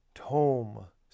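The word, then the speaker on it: tom (תם): The word here is tom, sounds like home. You might see it spelled out T-O-M, but again, it's from these Hebrew characters. So phonetically, it's tom.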